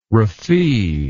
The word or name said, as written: raffee